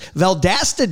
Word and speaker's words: Valdosta: Valdosta, Georgia.